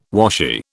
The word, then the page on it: washy: British English